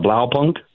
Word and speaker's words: Blaupunkt: I'm going to let you say it. Blaupunkt. – Is that Blaupunkt? – You did it. Yes.